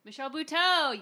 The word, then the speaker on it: Michelle Buteau: Michelle Buteau? – Michelle Buteau, yes!